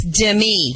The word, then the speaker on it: Demi: Is it Demi or Demi? – Demi's funny. – It's Demi? – It's Demi. – Demi. – It's on the second syllable.